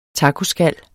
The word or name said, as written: tacoskal